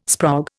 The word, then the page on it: sprog: Indian